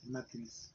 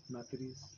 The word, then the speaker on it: matriz: Uterus pulled out, in Spanish it's matrix. – Matriz. – Huh. Wow, that, isn't that weird? – Matriz, yeah.